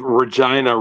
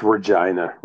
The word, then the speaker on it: Regina: It's the University of Regina rhymes with. – Yeah, got it. That's what it is. University of Regina. – Yeah, it's Regina rhymes with.